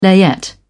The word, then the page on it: layette: British English